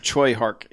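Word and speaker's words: Tsui Hark: And Robert in the chat has said Choi Hark is the correct pronunciation.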